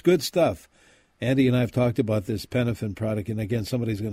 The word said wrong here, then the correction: Penofin: P-E-N, like pen." Penofin, P-E-N-O-F-I-N, Penofin.